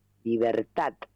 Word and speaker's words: libertad: Está lindo, porque dice, no hay que decir libertad, mi verdad. Hay que pronunciar la D como T, libertad. Y tienes razón, suena mejor, no,